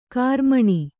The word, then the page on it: ಕಾರ್ಮಣ: Kannada